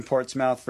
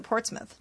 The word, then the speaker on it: Portsmouth: And also Nathaniel Miller from Portsmouth, Virginia. – Woo! I think they pronounce it Portsmouth, no? – Portsmouth, Virginia. No, they probably do. That makes sense. Like Dartmouth.